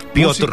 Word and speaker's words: Piotr: Piotr. Música. – Piotr. – Así me dijeron en la escuela de locución que había que pronunciar.